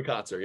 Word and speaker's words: Kotsur: The Coda actor, Kotsur, is that how you say it? – Troy Kotsur, yep.